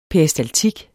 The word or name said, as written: peristaltik